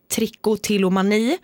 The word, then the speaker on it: trichotillomani: Vi har trichotillomani.